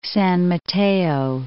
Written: San Mateo

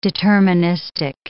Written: deterministic

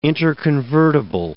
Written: interconvertible

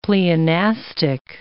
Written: pleonastic

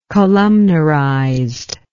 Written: columnarized

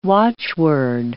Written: watchword

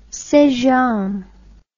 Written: st james

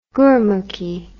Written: gurmukhi

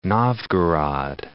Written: Novgorod

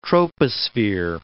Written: troposphere

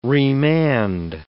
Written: remand

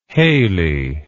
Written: hayley

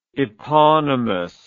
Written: eponymously

